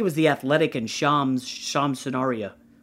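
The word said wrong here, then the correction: Charania: I probably butchered his name. – Shirinia. – Okay, thank you. – You said it wrong again. Shirinia. There you go.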